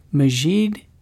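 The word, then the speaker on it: Maajid: Majid Nawaz, I use the pronunciation Majid because my wife's uncle has that name, so I'm used to saying Majid, but he says Majid Nawaz.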